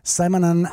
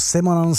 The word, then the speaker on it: Simonon: Po většinu své kariéry tvořili Clash zpěvák a kytarista Joe Strummer, kytarista, solový kytarista a zpěvák Mick Jones, baskytarista Paul Simonon a nebo Simonon, jsem taky slyšel výslovnost jeho příjmení, a bubeník Nicky Topper Hedden.